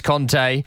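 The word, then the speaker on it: Kanté: It's like the N'Golo Kante, N'Golo Conte thing. He said his name is Conte, and if someone tells me that's their name, I won't go, all right, Kante, cheers, pal.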